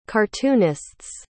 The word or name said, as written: cartoonists